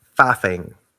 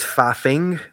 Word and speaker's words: faffing: Now, technically it's faffing with a G at the end, but we have a tendency to drop the G. We don't really pronounce G's at the end of our words the way that you guys might. So we wouldn't say driving. We would just say driving. So it's faffing technically, but we just say faffing.